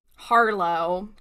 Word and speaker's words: Harlow: Harlow, get it right. – That would because it rhymes with Marlo, like Marlo Santeca from the High Republic.